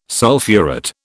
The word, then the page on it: sulphurate: British English